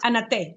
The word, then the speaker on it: Anate: Then you have, speaking about the Airbnb, it's pronounced Anate. – Anate, yes. Anate is the Spanish way to say the name Ana and the letter T.